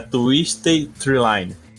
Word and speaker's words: Twisted Treeline: E o tema de hoje é Twisted Treeline. Falei certo? Falou, falou certinho, cara.